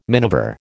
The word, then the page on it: miniver: American English